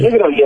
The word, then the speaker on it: Negroni: Negroni, así como lo pronunciamos.